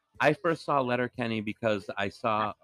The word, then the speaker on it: Letterkenny: Like, wait, am I saying it right? – Yeah, it is. Letterkenny.